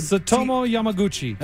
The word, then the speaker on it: Tsutomu Yamaguchi: How do you pronounce that word? – Tsutomu Yamaguchi. – Attaboy.